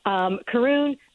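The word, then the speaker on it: Caorunn: So if you are into gin, I just poured a little, a half a dram of the Scottish gin Carún. Tell me about this. – I first of all, kudos to you. If I was there, I'd high-five you for correctly pronouncing that. That's amazing. Carún, very special, very unique gin, very, very popular in Scotland.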